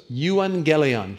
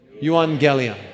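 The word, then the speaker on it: Euangelion (εὐαγγέλιον): But the word in the Greek there, and I want you to pronounce it with me. Here's how it goes. Euangelion. Try that. Euangelion.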